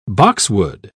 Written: boxwood

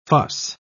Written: fuss

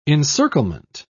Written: encirclement

